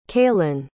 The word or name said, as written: kailyn